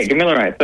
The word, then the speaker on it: Gamilaroi: Can you explain um, your native tongue in terms of it's uh, g- uh, Is that how it's pronounced? Yeah, Gamilaroi. Perfect. Yep, yep.